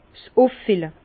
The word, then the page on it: s' Üffille: Bas-Rhin; Herrlisheim